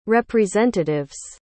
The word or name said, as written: representatives